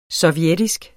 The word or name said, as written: sovjetisk